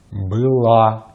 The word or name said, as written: была